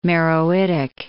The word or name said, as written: Meroitic